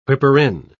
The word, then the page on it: whipper-in: British English